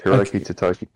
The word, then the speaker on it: Hiroki Totoki: Hiroki Totoki.